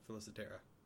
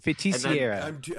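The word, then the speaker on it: Feiticeira: How do you pronounce this? – Felicitera. – There's no L there. – Oh, it's not even close to what it is. You're just making shit up.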